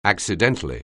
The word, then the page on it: accidentally: anglais